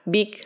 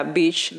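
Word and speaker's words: Bich: La madre de Jennifer Pan, Big Ha Pan, la verdad es que se escribe B-I-C-H, o sea, Beach Japan. Pan. Pero cuando yo estuve leyendo, decía ahí que se pronunciaba Big, entonces lo voy a decir así.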